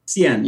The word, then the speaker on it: Cien: So you're CEO, founder of, and you pronounce it Cien? – Cien, yeah. – Cien. – And Cien means 100 in Spanish.